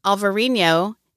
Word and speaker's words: Alvarinho: Alvarinho is the Portuguese spelling and pronunciation with a V like Victor, and Albarino with a B like boy is the Spanish spelling and pronunciation.